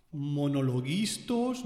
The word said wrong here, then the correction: monologuistas: monolistos, monolistas, monolingüistas, lingüística, pronunciación es lo que le falta a este tío. Monologuistas. Tan difícil no es.